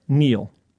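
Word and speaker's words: kneel: The K, which was silent until I spelled it earlier in the Neil.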